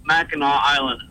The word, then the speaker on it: Mackinac Island: It's pronounced Mackinac Island. – Didn't I say that? – Yeah, that's what you said. – You know, you said Mackinac. It's spelled Mackinac, but it's pronounced Mackinac Island.